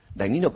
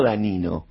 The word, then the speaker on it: Dagnino: Y el pico máximo fue en 1967, que declararon, un señor que a algunos les debe sonar, se llamaba Dagnino Pastore, no Danino, Dagnino, sí. Pastore. – Que, o por mejor decir, sí Dagnino, pero se escribía Dagnino. – Claro, el Danino Dagnino. – Se escribía Dagnino y se pronunciaba Dagnino.